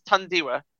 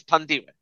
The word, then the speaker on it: Thandiwe: Hey, I was just hey, she wanted to be addressed as Thandiwe, so I'm addressing her as Thandiwe.